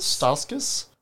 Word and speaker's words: Stauskas: Stauskas? – Stauskas.